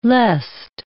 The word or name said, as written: lest